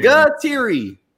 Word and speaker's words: Gatteri: Did I say it right? – You were close enough. You were close enough. – Larry, we went over this pre-show. You still butchered it. – I have it written down three times on my notepad in front of me. – Gatteri. – I got so David Gatteri.